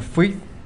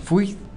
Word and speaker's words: Fjuiiith: lo pueden encontrar en Los Fui. Los, ¿cómo se escribe? – Esto se escribe F-J-U-I-I-I-T-H. Querían imitar así como un chiflido, como un Fui.